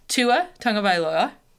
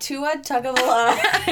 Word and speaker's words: Tua Tagovailoa: Joe Burrow to Cincy, Tua Tagovailoa.